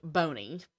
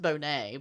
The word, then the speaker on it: Boney: One of the samples belonged to a former felon, Charles Bonney, which is spelled Boney, B-O-N-E-Y, which I think is funny, but it's pronounced Bonney, but I kind of want to just call him Boney because...